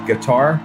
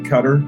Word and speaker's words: Qatar: Yesterday, we had a 15-hour flight back from what I like to call Qatar. They actually pronounce it Qatar, but it's Q-A-T-A-R, right? And they say Qatar. That's what they say, but it's not spelled C-U-T-T-E-R. It's like a guitar.